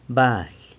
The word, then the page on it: fire: sanlucasquiavini